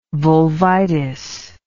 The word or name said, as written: vulvitis